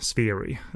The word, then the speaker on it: Shiri: The title Shiri, which is pronounced Sphiri, refers to a fish found in Korean freshwater streams.